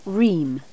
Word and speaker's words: Ream: My name's Ream. – How do you spell that? – R-E-A-M. – R-E-A-M.